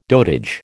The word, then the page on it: dotage: American English